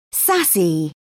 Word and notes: sassy: British English

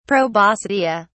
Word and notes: proboscidea: English; British English

